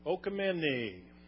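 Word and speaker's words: Oikoumene (οἰκουμένη): Now, the Greek word translated world in Matthew 24:14 is oikoumenai. Am I pronouncing that right? Oikoumene.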